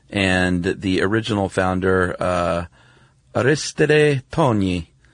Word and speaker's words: Tognis: Do you know how to pronounce this one? T-O-G-N-I-S? – I would say Tonis. – Tonis, that's what I was going to say.